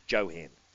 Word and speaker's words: Johan: Johan. Yeah, he's not a silent J. – He actually got interviewed by Matty Browning at Kembla recently, and Matty was calling him Johan, and he said, I'm terribly sorry. It's no silent J. It is Johan.